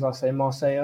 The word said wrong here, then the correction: Maresca: or Enzo Maresca. – Enzo Maresca.